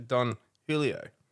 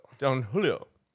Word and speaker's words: Don Julio: but totally forgot that the J is silent, or it's more like a H in Aussie language. So it's actually Don Julio. – Julio. – Don Julio.